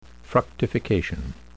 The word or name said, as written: fructification